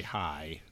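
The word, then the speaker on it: Haai: Even know how to say High, so it's H. – It's High, okay. I think. – But it's H A A I.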